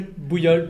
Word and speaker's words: Boulhol: And we're discussing that with Hervé Boulhol. Pronounced that correctly? – Bouillot, but that's fine.